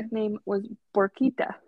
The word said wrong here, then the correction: Puerquita: Puerquita. Puerquita, like little piggy.